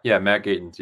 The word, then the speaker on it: Matt Gatens: Yeah, Matt Gatens.